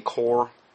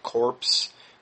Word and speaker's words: corps: He should be saying CORE. He's doing what Obama had done before, calling it corpse. It sounds like a corpse, like a dead body, and because it's spelled C-O-R-P-S, and he just should be saying CORE, but he evidently isn't aware of that, so I just want to clarify that.